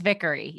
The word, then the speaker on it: Vickery: It's Vickery.